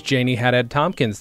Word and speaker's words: Janie Haddad Tompkins: My guest this week is Janie Haddad Tompkins.